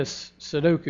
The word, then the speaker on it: Sudoku: She's really into Sudoku. Did I say that right? Yeah.